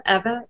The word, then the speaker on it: Eva: Get it away because people are always like, is it Ava? Eva?